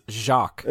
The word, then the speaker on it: Jacques: You should know how to pronounce this one. I'm pretty sure it's Jacques. – It is Jacques.